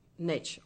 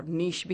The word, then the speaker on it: niche: Let's talk about how you're going to find uh, your niche or niche भी कहते हैं or niche भी कहते हैं. It's spelled N-I-C-H-E.